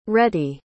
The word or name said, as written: rettie